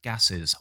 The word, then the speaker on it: gases: You got co-sponsored by Environmentally Safe Gashes. Not gashes. That was a mispronunciation. sorry, guys. Co-sponsored by Environmentally Safe Gases.